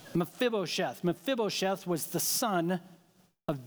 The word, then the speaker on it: Mephibosheth: One last illustration of this, David understood, in 2 Samuel chapter 9, David extended friendship to a guy named Mephibosheth. Mephibosheth. Took me 30 minutes to say that correctly. Mephibosheth.